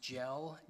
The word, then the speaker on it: Gell: Well, Your Honor, I hesitate only because this court's decision in, I think it's pronounced GELL, G-E-L-L, that's cited in the state's brief.